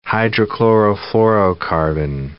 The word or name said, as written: hydrochlorofluorocarbon